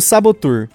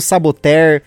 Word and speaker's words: Saboteur: Queria só fazer um elogio maravilhoso aí para Maria Eduarda pela pronúncia perfeita e correta do Sabotur, né? Olha aí que maravilha, a gente vê o pessoal falando saboter, sabotador, um monte de coisa. Ela fez a pronúncia perfeita,